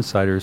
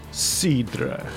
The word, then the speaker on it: cidre: You're supposed to pronounce it cidre. – Cidre. – So it's not cider. It's cidre.